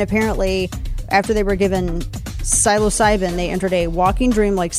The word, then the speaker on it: psilocybin: Also on deck here, a compound in magic mushrooms is apparently, I can't even pronounce this, psilocybin? Sounds right.